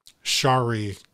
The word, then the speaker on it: Chari: Shari Perry. So it's P-E-R-E. And her first name is spelled C-H-A-R-I. – Shari.